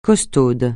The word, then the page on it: costaude: francais